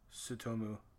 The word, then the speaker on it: Tsutomu: I think that's a silent T. Tsutomu Miyazaki.